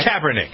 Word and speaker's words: Kaepernick: Kaepernick.